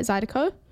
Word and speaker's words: Zydeco: Is that, am I saying that right? Zydeco? – Yep.